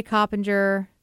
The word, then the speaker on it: Coppinger: Ray Coppinger. Am I saying that right? – Yep.